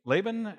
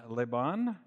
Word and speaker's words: Laban: I've heard it pronounced both ways, Laban and Laban. – Yeah, you can okay. Say it either way.